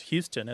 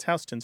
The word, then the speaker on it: Houston: Many people from the rest of the country assume that it's pronounced Houston, as in Sam Houston. But it isn't. It's Houston Street, spelled exactly the same way.